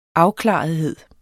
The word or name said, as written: afklarethed